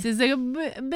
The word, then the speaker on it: Syzygy: Syzygy, blah, blah, blah, blah. Did I say that right? – Yeah, you nailed it.